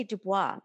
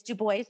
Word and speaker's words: Du Bois: I always say Du Bois, but it's Du Bois.